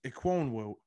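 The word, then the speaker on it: Ekwonu: I'm going to go with Ikem Inakwu. I probably butchered his name. – But Ikwonu, I think it is. – Ikwonu.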